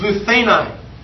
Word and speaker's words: luthenai: This word broken in the Greek is luthenai. L-U-T-H, long A, N-A-I.